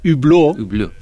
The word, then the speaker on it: Hublot: Yo para terminar quiero que él nos diga cómo se dice realmente Hublot, que lo decimos fatal, y como despedida de agradecimiento, cómo se dice, cómo se pronuncia. En francés evidentemente, es Hublot, pero como decía, es, ojo de buey, que es lo que creo que, en el diseño todo el mundo lo reconocerá.